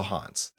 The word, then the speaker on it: Hans: H-A-N-S. – So Hans.